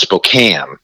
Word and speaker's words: Spokane: Okay, you know, and and actually, he is, the, the the city is pronounced Spokane, so it's mispronounced.